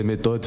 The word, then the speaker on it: Meiitod: Instagram c'est méthode, Facebook c'est méthode, M2ITOD. Voilà, ça s'écrit particulièrement, hein. c'est M-E-2-I-T-O-D, méthode.